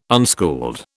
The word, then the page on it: unschooled: British English